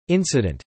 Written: incident

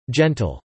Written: gentle

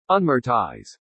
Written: unmortise

